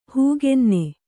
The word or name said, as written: ಹೂಗೆನ್ನೆ